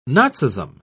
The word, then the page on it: nazism: British English